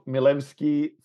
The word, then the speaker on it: Milewski: We're also joined by, and I'm going to try not to mess this up too much, Todd Milewski from Wisconsin State Journal. I hope I pronounced the surname reasonably well. – That's better than actually, it's more appropriate to how it should be pronounced, and it actually gets pronounced by my family.